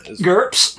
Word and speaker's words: GURPS: GURPS? – Yep. – Like that? Yeah, okay. Yeah. Right. Because it rhymes with burps.